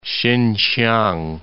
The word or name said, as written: Sinsiang